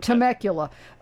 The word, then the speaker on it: Temecula: And then at that point, Is it pronounced Temecula? – Temecula.